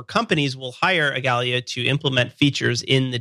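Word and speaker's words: Igalia: Because you work for Agalia. Is that how you say it? – Yes.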